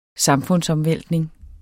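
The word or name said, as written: samfundsomvæltning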